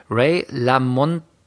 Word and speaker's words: LaMontagne: Ich habe Ray, so jetzt wird es schwierig, Ray Lamontagne oder also den schreibt man La Montagne. Ich habe keine Ahnung, wie man ausspricht. – La Montagne. – Oder so.